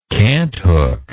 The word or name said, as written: canthook